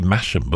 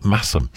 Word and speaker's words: Masham: Saturday, I was in the town of Masham, which is always mispronounced because it looks like it should be Masham, but Masham in North Yorkshire.